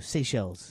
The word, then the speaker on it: Seychelles: Seychelles.